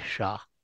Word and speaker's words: Shah: My name is Bileen, that is spelled V as in Victor, I-L-E-E-N, and last name is Shah, S-H-A-H.